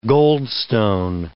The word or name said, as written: goldstone